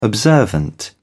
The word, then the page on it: observant: British English